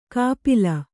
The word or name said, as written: ಕಪಿಲ